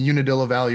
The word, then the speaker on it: Unadilla Valley: So you were the first, is it pronounced Unadilla Valley? – Yep. – The Unadilla Valley.